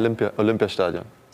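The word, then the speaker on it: Olympiastadion: Um, Olympic St- Stadion? How do you say that? Olympia, Olympia Stadion.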